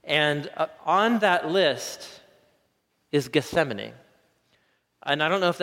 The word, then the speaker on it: Gethsemane: It's hard to say in English, isn't it? Gethsemane. It's easier to say in Hebrew than it is in Gat Shamane is how you say it in Hebrew. Gethsemane. Something about the THS. Anyway, Gethsemane.